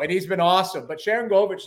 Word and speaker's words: Sharangovich: What's Sharangovich? – Yep.